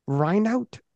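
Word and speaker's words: Reinhoudt: The first IPF super heavyweight champion was Don Reinout. Is that how I pronounce his last name, Matt? – Mm-hmm. – Yeah, it's close enough.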